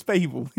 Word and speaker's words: Babel: No, it's Babel. It's Babel. Journey to Babel. We're about to watch the episode, so we'll find out. So in 10 forward, you can lambast me for being incorrectly pronunciating, or we can not. I'm sure it's Babel.